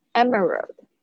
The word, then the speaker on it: emerald: 哦，这个 a l 的发音是发 earl，earl 的声音。 Emerald，好，绿翡翠就有点像是绿的石头，有点像是我们那个，嗯，反正就是是石头，的不透明的，是绿色的。